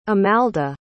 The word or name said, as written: amalda